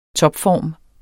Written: topform

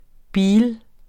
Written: bil

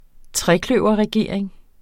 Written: trekløverregering

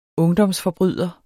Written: ungdomsforbryder

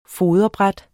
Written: foderbræt

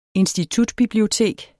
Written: institutbibliotek

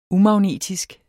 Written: umagnetisk

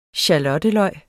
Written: skalotteløg

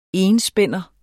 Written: enspænder

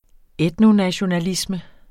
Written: etnonationalisme

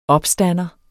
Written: opstander

